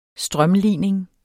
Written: strømlining

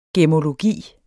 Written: GEMMOLOGI

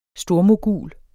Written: stormogul

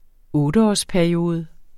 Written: otteårsperiode

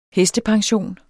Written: hestepension

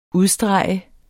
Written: UDSTREGET

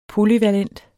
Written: polyvalent

